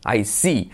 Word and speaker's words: I see: And I see se escribe con doble E, no una, sino dos, para que pensamos que se dice de otra forma, pero no, se dice I, I see.